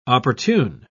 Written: opportune

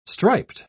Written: striped

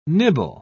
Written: nibble

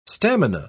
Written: stamina

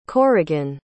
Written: corrigan

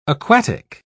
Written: aquatic

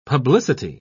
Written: publicity

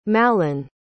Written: mallon

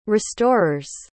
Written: restorers

